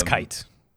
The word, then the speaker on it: Kite: Now, wait, is it pronounced Kite or Kite? – I have no idea. That's a good question. I've only ever seen it written out. – I'm looking it up. Continue. It's Kite.